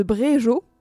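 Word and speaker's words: Brégeot: Bréjo j'espère que je prononce correctement G-E-O-T ça se prononce Jo on va dire que oui. oui, je dirais ça.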